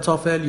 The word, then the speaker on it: TOEFL: بعد از معرفی IELTS این بار میخوام در مورد آزمون TOEFL باهاتون صحبت کنم. لغت TOEFL یا با تلفظ درست TOEFL مخفف عبارت Test of English as a Foreign Language هست.